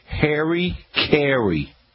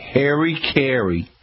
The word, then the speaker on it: Hari-Kari: So if you're getting a lot of beetles and you want to knock them down instantly, look for a product called Harry Carry. And it's spelled H-A-R-I-K-A-R-I, Harry Carry.